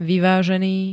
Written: vyvazeny

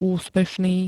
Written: úspešný

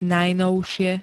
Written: najnovšie

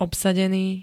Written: obsadený

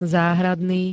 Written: záhradný

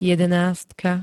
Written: jedenástka

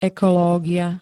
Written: ekológia

